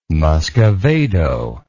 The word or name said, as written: muscovado